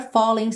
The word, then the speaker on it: falling: You're a falling star. Falling, o som termina no N, o G não é pronunciado, por isso está pintadinho de cinza claro, certo?